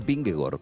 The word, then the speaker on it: Vingegaard: La doble A en los idiomas escandinavos se pronuncia como una O. Es decir, el ciclista danés Vingegaard es Vingegaard, por raro que suene.